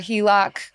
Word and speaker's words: HELOC: I recently got approved for a HELOC. Is that how you pronounce it? – That is how you pronounce it.